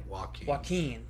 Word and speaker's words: Joaquin: He TKO'd the KO of the Year candidate in Jaquin. Is that how you say it? – Joaquin. – Damn it. I always mess up his first name.